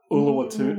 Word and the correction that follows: Oluwatimi: Oluwatimi, Oluwatimi, yeah, Oluwatimi.